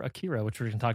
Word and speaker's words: Akira: But right now, let's get into our main discussion for the evening, which is 1988's anime classic Akira, pronounced Akira.